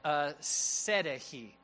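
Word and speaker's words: Syntyche: In fact, Paul talks about two ladies in the church, and here's how you pronounce their names. It's Euodia and Sedehi. That's how you actually pronounce these names.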